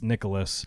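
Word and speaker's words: Nicolas: I mean, people get my first name wrong, too, because it's, well, my full, it's Nicholas, but with no H.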